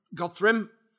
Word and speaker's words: Godthrymm: Godthrymm. – Yeah. – I've said it right. – Yep.